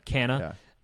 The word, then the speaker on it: Canha: Mark Canha. I think it's Canha. Canha. Right? – Canha. Yeah. Yeah, all these guys are free agents.